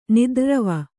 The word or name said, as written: ನಿದ್ರ್ರವ